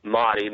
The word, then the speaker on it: Mahdi: The Mahdi or the Mahdi. Some pronounce it Mahdi, means the guided one.